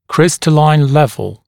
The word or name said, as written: crystalline level